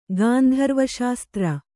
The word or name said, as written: ಗಾಂಧರ್ವಶಾಸ್ತ್ರ